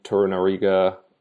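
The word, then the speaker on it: Torunarigha: Kan Ayen of Fortuna Dusseldorf, and at left back I went with Jordan Torunariga. I think that's correct pronunciation. Sorry. – No, that's bang on.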